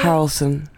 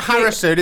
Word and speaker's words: Harrelson: Harrelson. – Harrison. – Harrelson. – This is compelling radio. It's not Harrison, it's Harrelson.